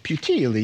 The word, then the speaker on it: Puteoli: Puteoli. You're going to say it like you're making a pizza.